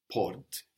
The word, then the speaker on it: porte: But this T is not on the end of porte. Porte is spelled P-O-R-T-E. The same with trente, 30, which is spelled T-R-E-N-T-E. So the final E means the consonant isn't on the end of the word anymore. And so we know that we should pronounce it, porte.